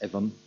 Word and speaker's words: eidheann: Now, this you get this in I suppose sporadically in modern dialects, such as the word which is traditionally spelled G-U-I-D-H-E, is in certain dialects in Connacht pronounced Guivhe. The word for ivy, which is traditionally spelled E-I-D-H-E-A-N-N, is in some dialects pronounced Evan. Right? The place name Glasnevin, the suburbs of Dublin, is originally N-A-O-I-D-H-E-A-N, something like Nevin, and so on, but sporadic.